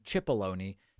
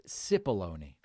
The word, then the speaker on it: Cipollone: Trump is referring to his former White House counsel, Pat Cipollone, which I last week was pronouncing Cipollone. People very politely corrected me, and they said it is actually only pronounced Cipollone.